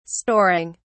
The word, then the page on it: storing: British English